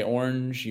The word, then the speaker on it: orange: How many syllables in orange? – Oh, I'm on mute. That was a Billy Ray mistake right there, man. it's two.